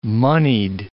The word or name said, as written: moneyed